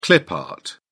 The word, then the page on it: clip art: British English